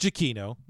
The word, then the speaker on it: Giacchino: It's pronounced Giacchino because I'm a big soundtrack nerd,